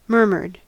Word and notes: murmured: American English

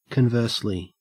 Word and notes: conversely: Australian English

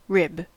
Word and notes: rib: American English

